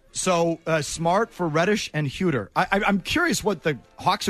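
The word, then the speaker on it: Huerter: Huter.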